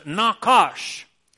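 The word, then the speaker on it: nachash: And one of the Hebrew words for serpent, way back in the Old Testament, is pronounced nakash.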